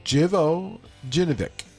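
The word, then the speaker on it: Živojinović: I think you're leaving out a syllable. – No. Jivojinovic. – Yeah, there you are. – Yeah, did I mispronounce it? I'm sorry. – You got it. No. When you first did it, you left out one of the syllables. You may have left out a couple syllables.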